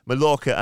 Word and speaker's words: Mallorca: Um, so yeah, so you're in Palma, Mallorca, and uh, Mallorca or Mallorca, Mallorca as the locals say.